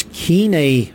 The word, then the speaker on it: Koine: There's a lot of people say Koine Greek, but that's not proper. That's academic Greek. The proper way to say it is Koine Greek, which was the common tongue.